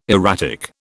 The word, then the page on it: erratic: British English